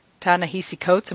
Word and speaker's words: Ta-Nehisi Coates: really good article in The Atlantic by Ta-Nehisi Coates.